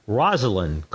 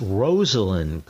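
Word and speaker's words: Rosalynn: Everybody says Rosalind Carter. Not correct. It's Rosalind Carter.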